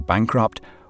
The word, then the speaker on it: bankrupt: Now be careful how you pronounce these two words, bankrupt with a T at the end, and bankruptcy where you drop the T at the end.